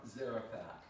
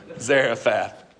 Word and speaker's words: Zarephath: Zarephath. Sounds good. All right. Zarephath, right?